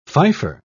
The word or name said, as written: fifer